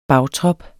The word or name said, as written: bagtrop